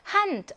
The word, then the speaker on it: Hand: Hand, 알파벳 D이긴 하지만 어디에 있어요? 단어 음절 끝에 있어요. 그럼 무슨 발음? 트 발음 납니다.